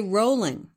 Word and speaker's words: Rowling: J.K. Rowling, which is how she pronounces it, even though some people think it's Rowling.